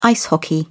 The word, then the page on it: ice hockey: British English